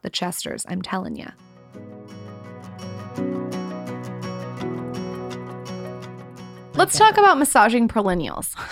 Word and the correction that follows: perineum: Is that how you say it? – No, perineum. – Perineum.